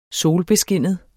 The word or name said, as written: solbeskinnet